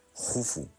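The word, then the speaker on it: Khufu: Y eso lo aprovecha muy bien su hijo Keops, que por cierto, para Culturilla General, se escribe Keops, pero se pronuncia Jufu,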